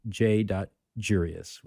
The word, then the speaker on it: J.Jurius: Sort of rhymes with curious, but it's J.Jurius with a J.